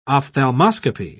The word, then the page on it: ophthalmoscopy: British English